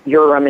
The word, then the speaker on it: Uram: It's pretty much any way you say it's okay, because half the family says Uram and half the family says Uram.